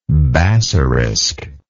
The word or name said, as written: bassarisk